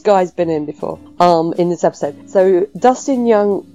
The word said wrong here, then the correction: Dustin Nguyen: was... Oh, actually, it's pronounced Dustin Nguyen.